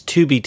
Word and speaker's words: Tubi: It's Tubi TV. – Are you assume that they're pronounced Japanese? It could be pronounced English. – Even in English, that's not Toby TV. – Tubi TV.